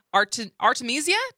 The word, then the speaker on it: Artemisia: But first of all, congratulations on winning the Charles Ives Opera Prize for your new opera, Artemisia. Did I pronounce that correctly? – That's absolutely right.